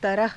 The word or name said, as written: taraha